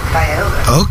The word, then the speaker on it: Cuyahoga: Yeah, Cuyahoga.